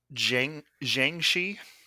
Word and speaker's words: Jiangshi: Jiangshi. – Jiangshi. – Yeah. In Chinese folklore. I almost said Jianshi. So I almost butchered the hell out of that word.